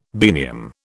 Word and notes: biennium: British English

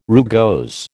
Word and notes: rugose: American English

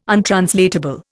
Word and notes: untranslatable: Indian

